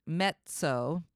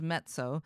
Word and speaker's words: mezzo: Also, you, you, when you guys see it, it's written as mezzo, but it's like pizza. This is the way you pronounce it in Italian, mezzo. So just realize that's what it is, mezzo.